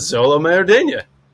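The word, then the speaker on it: Xolo Maridueña: I'm gonna spell it first. It's X-O-L-O. First name is X-O-L-O. Last name, M-A-R-I-D-U-E-N-A. – As Zolo Maridueña. – I was going to go with Zolo, and then I was probably would have said Maridueña. Maridueña. – Maridueña sounds good.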